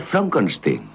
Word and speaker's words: Frankenstein: ¿Me toma el pelo? No, se pronuncia Frankenstein.